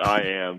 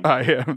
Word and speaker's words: I Am: How am I supposed to say that correctly? I am? I am blank? – Yeah, I just call it I am. – I am. There you go.